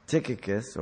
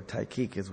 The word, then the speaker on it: Tychicus: Notice Paul made known to the Ephesians that Tychicus, or Tychicus, whichever you want to pronounce it, would inform them of these things.